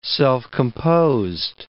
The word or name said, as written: self-composed